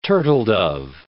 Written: turtledove

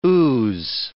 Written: ooze

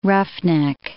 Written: roughneck

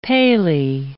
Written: paly

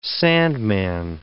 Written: sandman

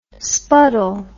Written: spuddle

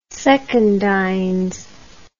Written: secundines

